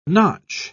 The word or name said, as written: notch